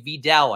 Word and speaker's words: Vidal: David Montgomery, Devin Singletary, Jerome Ford, Marshawn Lloyd, Tyler Allgeier, Kamani Vidal, a.k.a. Vidal, I believe is how you're supposed to pronounce his name.